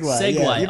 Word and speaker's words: segue: For a very long time, I didn't associate the word S-E-G-U-E. – Oh, segue. Segway. Yeah, I pronounce it seg. Well, that's in my head, I'm like, oh, it's a real red seg.